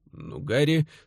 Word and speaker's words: Нугари: Нугари с ударением на втором слоге.